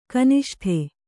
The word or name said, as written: ಘನಿಷ್ಠ